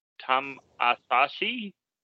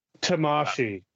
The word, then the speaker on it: Tamashii: So New Japan Pro Wrestling launches the new Tam Asahi. My apologies there on the pronunciation. – Tamashi.